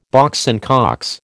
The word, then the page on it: Box and Cox: American English